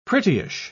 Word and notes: prettyish: British English

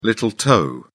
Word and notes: little toe: British English

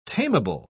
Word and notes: tameable: British English

